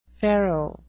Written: pharaoh

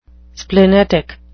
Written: splenetic

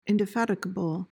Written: indefatigable

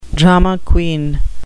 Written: drama queen

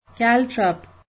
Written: caltrop

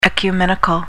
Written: ecumenical

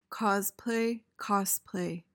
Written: cosplay

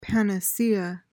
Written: panacea

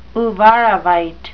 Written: uvarovite

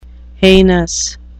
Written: heinous

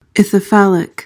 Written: ithyphallic

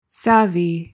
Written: savvy

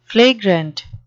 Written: flagrant

